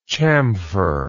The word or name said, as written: chamferer